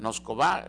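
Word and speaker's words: Nosková: en este caso Linda Noskova, más bien, Noskova, está pronunciado así mejor,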